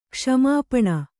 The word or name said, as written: ಕ್ಷಮಾಪಣೆ